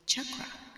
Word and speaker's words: chakra: Chakra is a Sanskrit word, and the correct pronunciation of the word is chakra. We are not quite sure who and when people started saying the word chakra incorrectly. And why? Because even if you're a native English speaker, chakra is spelled C-H. And just like chalk, you'd pronounce it naturally ch.